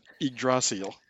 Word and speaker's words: Yggdrasil: Yggdrasil.